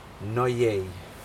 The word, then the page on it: noyei: Aneityum